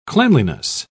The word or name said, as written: cleanliness